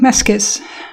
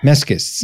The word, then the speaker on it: Mascis: I hope I pronounced his name right. – Yeah, I think Mascis. – Mascis.